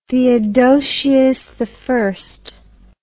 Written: theodosius i

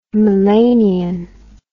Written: melanian

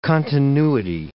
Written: continuity

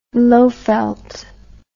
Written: lowveld

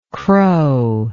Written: crowingly

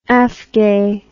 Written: afgay

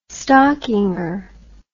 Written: stockinger

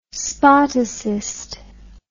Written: spartacist

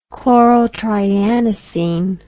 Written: chlorotrianisene

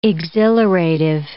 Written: exhilarative

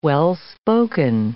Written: well-spoken

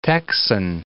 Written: texan

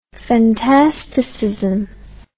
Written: fantasticism

